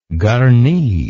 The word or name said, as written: garni